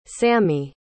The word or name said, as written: sami